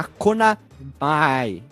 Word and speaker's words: Konami: E é claro, foi desenvolvido e publicado pela Konami na época que ela fazia alguma coisa, ou como eu inventei a pronúncia, a Konamai, que é a pronúncia certa.